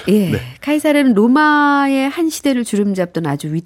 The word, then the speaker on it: 카이사르: 근데 이 시저는 카이사르의 영어식 발음이고 아. – 카이사르는 고대 로마 언어였던 라틴어 발음이라고 합니다.